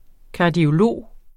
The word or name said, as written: kardiolog